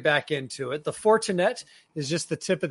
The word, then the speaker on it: Fortinet: The Fortinet, is that how you say it? But is it the Fortinet? I'm probably saying it wrong. – Fortinet. Yeah, Fortinet. I'm gonna go ahead and early and disagree with you, and we're gonna go ahead and start the show off like that.